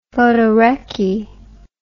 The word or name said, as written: photorecce